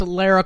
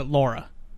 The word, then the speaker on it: Lara: So he pointed out that I pronounced Lara Croft's name wrong. I pronounced it Laura. – Yeah, you say Laura. – I say Laura. – It's supposed to be Laura. – It's supposed to be Lara, or yeah, whatever you want to fucking say.